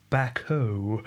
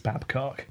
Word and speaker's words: Babcock: Perhaps pronounced Bacco. – Bacco. – But no, it's Babcock.